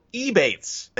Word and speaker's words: Ebates: It rhymes with rebates, which is kind of like what you're getting. Rebates, Ebates, great.